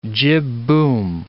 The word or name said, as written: jibboom